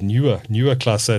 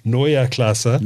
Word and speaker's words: Neue Klasse: I call it Neue Klasse. It's actually Neue Klasse. – Neue Klasse. Neue Klasse. Okay, Klasse. There we go.